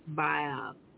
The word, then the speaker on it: Baal: Baal.